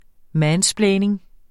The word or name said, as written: mansplaining